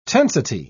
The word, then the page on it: tensity: British English